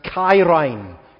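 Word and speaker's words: kairine (χαίρειν): Here is Paul subverting the typical Greek greeting, the Greek letter writing convention pronounced kairine, greetings.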